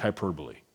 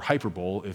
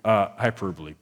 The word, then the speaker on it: hyperbole: This is just some good old-fashioned ancient Greek hyperbole, right? Or hyperbole, if you read phonetically like I do, right? It's just some good old-fashioned ancient Greek hyperbole.